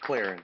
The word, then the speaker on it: Clarin: How you say your last name? Clarin? Is that correct? – Clarin.